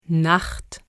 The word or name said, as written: Nacht